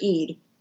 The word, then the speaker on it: Eid: It's just pronounced Eid is the name of the, I guess it's a festival or a feast that they have at the end of Ramadan.